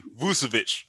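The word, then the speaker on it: Vučević: Vucevic.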